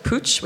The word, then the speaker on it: Puig: And around 3 years ago, I joined a group called Pooch, which nobody knows how to say. It's spelled P-U-I-G. I called it Puig myself before, but it's a Spanish group.